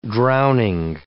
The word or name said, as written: drowning